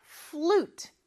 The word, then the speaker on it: flute: I play the flute. 자, 우리는 플루트라고 하는데, flute 하나의 음절로 발음해 주세요.